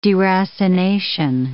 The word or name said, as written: deracination